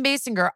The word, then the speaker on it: Basinger: Kim Basinger, is that how you say it? Basinger? – You know, no, Kim Basinger.